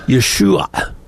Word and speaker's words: Yeshua: which is called ayin. And we don't have that sound in English. – No, no. – It's an ah sound. We it doesn't exist for us. – Yeah. – So the proper pronunciation would be Yeshua. But again, most Americans can't make that last sound. They can do the SH, not the ayin.